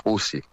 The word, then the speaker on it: Houthi: e sono quelli che conosciamo in italiano come gli Houthi, ma la pronuncia è Houthi,